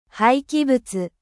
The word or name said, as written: dechets